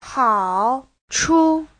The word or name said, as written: hao3chu5